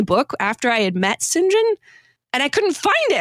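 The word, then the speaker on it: St John: My favorite, and this is such a detail, but my favorite part was when I realized that the name STJOHN is pronounced Sinjin, because I was reading, listening to the book, and I was like, Sinjin, that's an odd name, but okay.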